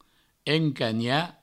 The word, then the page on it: encanhar: encagna